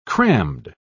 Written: crammed

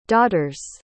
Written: daughter's

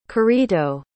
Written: currito

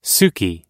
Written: suki

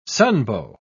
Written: sunbow

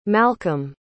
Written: malcolm

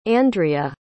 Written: andrea

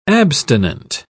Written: abstinent

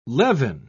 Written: levin

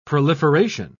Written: proliferation

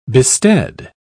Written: bestead

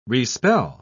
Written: respell

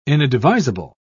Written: inadvisable

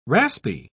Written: raspy

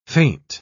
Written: feint

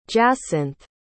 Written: jacinthe

